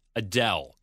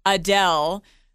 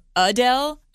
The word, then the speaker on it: Adele: So most people say Adele, but she's saying put emphasis on the second syllable instead and say it more Adele.